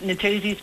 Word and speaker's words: Nathusius: a Nathusius pipistrelle, is it? – Correct, Nathusius pipistrelle, yes.